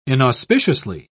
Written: inauspiciously

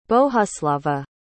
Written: bohuslava

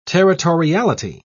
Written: territoriality

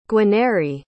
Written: guarnieri

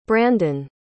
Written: brandon

